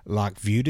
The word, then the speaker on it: Lac Vieux: So Kyle says, I fish Lac Vieux, V-I-E-U-X. – Oh, Lac Vieux d'Azur? – Lac Vieux d'Isère.